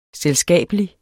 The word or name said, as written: selskabelig